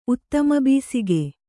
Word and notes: ಉತ್ತಮಬೀಸಿಗೆ: uttamabīsige

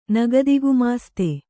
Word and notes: ನಗದಿಗುಮಾಸ್ತೆ: nagadi gumāste

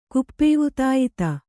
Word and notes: ಕುಪ್ಪೇವುತಾಯಿತ: kuppēvutāyita

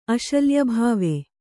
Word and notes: ಅಶಲ್ಯಭಾವ: aśalyabhāva

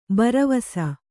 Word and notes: ಭರವಸ: bharavasa

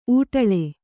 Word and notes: ಊಟಳೆ: ūṭaḷe

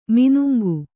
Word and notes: ಮಾನಂಗಿ: mānangi

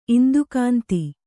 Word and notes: ಇಂದುಕಾಂತಿ: indukānti

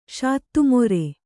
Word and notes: ಶಾತ್ತುಮೊರೆ: śattu more

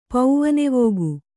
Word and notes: ಪವನವೇಗ: kannada

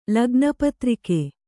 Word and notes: ಲಗ್ನಪತ್ರಿಕೆ: Kannada

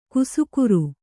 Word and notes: ಕುಸುಕುರು: kusukuru

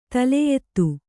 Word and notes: ದಲಾಯತ್: dalāyat

